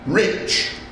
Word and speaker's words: rich: "Our English adverbs do not terminate in mong, and we pronounce the ch as if there was a t before it. We say rich."